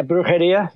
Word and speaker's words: Brujeria: And you also did, was it a grindcore, Brujeria? Is that, am I pronouncing that right? – Yes. Yeah, Brujeria.